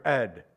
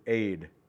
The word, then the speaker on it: Ed: I can't get past that, but the real pronunciation is Aid.